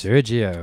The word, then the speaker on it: Sergio: Sergio, or is it Sergey? I always say his name wrong. – It's Sergio.